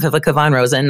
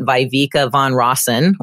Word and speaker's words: Viveka von Rosen: So A, you know how to say my name, Vivica Von Rosen, not Vivica Von Rosen, which I get all the time.